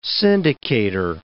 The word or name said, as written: syndicator